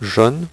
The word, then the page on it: je ne: French